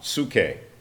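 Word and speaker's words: Psuche (ψυχή): Psuche. Actually starts with a P, but the P is silent.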